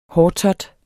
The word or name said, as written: hårtot